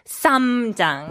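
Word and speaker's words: ssamjang: But the just make sure you focus on that pronunciation. We all struggle with the sang kulcha, the sang shiot. It's the samjang. – Right. Like right when there. you pronounce that S, the tongue kind of goes to the roof of the mouth and yeah.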